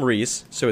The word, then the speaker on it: Reese's: So it belongs to a man named Reese. So it's Reese's, and then you just say the word pieces like you normally would.